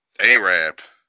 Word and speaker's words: Arab: The geographic part of the world where you said somebody you put the wrong stress on the wrong vowel. – Arab. – Right. See, that's it.